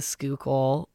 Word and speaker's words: Schuylkill: Like the Schuylkill or Conshohocken or Wissahickon. Those I'm pretty sure I'm saying right.